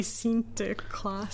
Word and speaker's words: Sinterklaas: Here in the Netherlands, we have a thing called Sinterklaas. How's that pronounced? – Sinterklaas? – Sinterklaas. You're half Dutch, aren't you? – I am. – How do you say Sinterklaas? – I don't speak it. It'd be Sinterklaas.